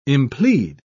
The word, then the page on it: implead: British English